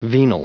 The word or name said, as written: venal